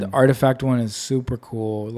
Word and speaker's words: RTFKT: I forget that it's the R. It's spelled RF. – I read that the other day on Twitter. Like, I just found out that RTFKT is pronounced Artifact.